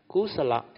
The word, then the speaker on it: kusala: That's why they say under Abhidhamma, when you input the content of consciousness, this content of consciousness, there are a few types, kusala and akusala. You pronounce it like Malay word, very easy.